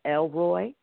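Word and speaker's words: El Roi: We'll be talking about one of the names of God, and that's Elroy. And it's spelled E-L, capital E, then lowercase L, and then Roy, capital R-O-I.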